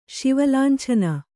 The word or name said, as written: ಶಿವಲಾಂಛನ